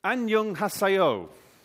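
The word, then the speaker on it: Annyeonghaseyo (안녕하세요): Annyeonghaseyo.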